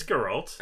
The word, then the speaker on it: Geralt: I think it's Geralt. – Geralt. – Geralt. We'll find out. I think it's with a hard G.